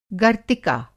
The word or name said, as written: गर्तिका